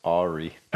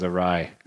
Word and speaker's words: awry: When life goes awry. – Or as Sean likes to say, as life goes awry. – Awry. Yeah. Oh, that's how you say it.